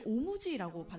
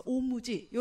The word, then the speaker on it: 엄지: 엄지는 그 엄지를 오무지라고 발음을 한대 일본에서는 그래서 오무지 이런 식으로 음.